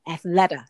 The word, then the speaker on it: Athleta: applause to Allyson Felix and Simone Biles, who have fantastic lines at Athletica. Athleta, sorry. Athleta, yes. – And no, it's actually pronounced Athleta.